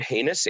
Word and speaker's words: Hanus: You can Google Richard Hanus, H A N U S.